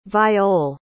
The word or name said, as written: viole